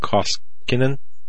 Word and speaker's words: Koskinen: You remember John Kosikin. John Koskinen. Yeah, that's how you pronounce his name.